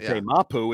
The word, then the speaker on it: Marte Mapu: Another guy who I'm going to check the pronunciation because Ross Tucker said it very differently than we say it. Marty Mapu. – Yeah, he called Marte Mapu is how we say it. He says Marty Mapu.